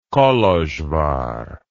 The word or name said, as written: kolozsvar